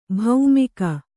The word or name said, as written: ಭೈಮೂಗು